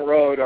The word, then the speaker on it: Rode: It's Tim Road, R-O-D-E, at trtoppers.